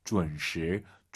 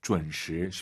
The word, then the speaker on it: 准时: 준시, 준시 아니에요. UN 사이에 으어 들어가야 돼요. 준, 준시, 준시. 쉽지 않습니다. UN 발음을 어려워하시면은요, 결혼하다를 떠올리세요. 결혼하다, 지혼 아니죠?